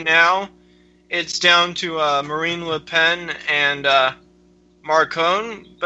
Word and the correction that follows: Macron: And it's Macron is the other one, not Marcon.